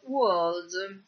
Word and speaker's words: world: Per quanto riguarda la pronuncia, vi voglio far notare la parola mondo, world, che inizia di nuovo con la nostra W, quindi dobbiamo inizialmente emettere una U e poi quella R non si fa sentire.